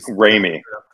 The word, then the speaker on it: Raimi: When Ramley, how do you say his last name? – Raimi. – Raimi.